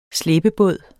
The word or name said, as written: slæbebåd